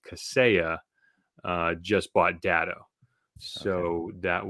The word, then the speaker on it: Kaseya: That's Kaseya. K-A-S-E-Y-A. Kaseya. There's Kaseya.